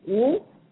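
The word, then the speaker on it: Wu (武): So the Chinese character for martial arts is Wu, and also the Chinese character for dance is Wu, W-U. So it's the same pronunciation.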